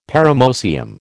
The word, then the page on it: paramoecium: American English